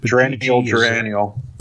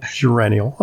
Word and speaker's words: geraniol: Geraniol. – Thank you. – Yeah. – Geraniol.